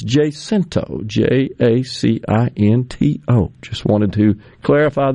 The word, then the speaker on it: Jacinto: Now, yesterday, the candidates made their way up to Jacinto, Alcorn County. I think that's where we finally settled. That's where it is, right? So my good friend Nick Bain, who represents the area, says, yeah, it's Jacinto is the proper pronunciation.